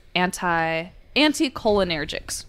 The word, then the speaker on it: anticholinergic: The plant contains potent tropane alkaloids, all of which are classified as anticholinergics,